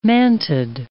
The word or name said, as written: mantid